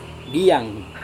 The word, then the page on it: diang: Serewen